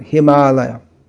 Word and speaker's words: Himalaya: Just like you know the word Himalaya, which in English is pronounced Himalaya, but in Indian languages is pronounced Himalaya.